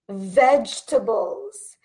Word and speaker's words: vegetables: The correct pronunciation is vegetables, vegetables. And it even has like a ch sound in there. Vegetables, vegetables, okay? So this is almost like a ch, vegetables. So we do not pronounce this E. This E right here is silent, okay?